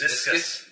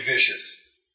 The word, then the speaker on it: viscous: Viscous. – Viscous material. – I can never read it. Viscous liquid. – Just don't say vicious.